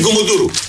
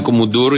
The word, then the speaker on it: Κουμουντούρου: Είναι Κουμουντούρου ή Κουμουντούρου, ανάλογα πώς θα το δει κανείς, δεν είναι Κουμουντούρου. Για Κουμάτο ήταν ο ένας, Βέγκος ήταν ο άλλος, νομίζω και ο Τράγκας το λέει έτσι. Κουμουντούρου, το τονίζει στο αλλού. Α, στο πρώτο, Κουμουντούρου το λέει και ο Τράγκας λοιπόν.